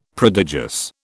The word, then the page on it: prodigious: British English